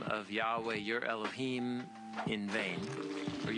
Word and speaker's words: Yahweh: In the Hebrew Bible, God's name, spelled with four Hebrew letters, Yod, Hey, Vav, Hey, and the most ancient pronunciation that we're pretty sure we can recover of it would have been Yahweh.